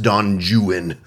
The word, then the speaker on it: Don Juan: It's Don Juan, but it's pronounced Don Juan in British English.